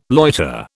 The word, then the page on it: loiter: British English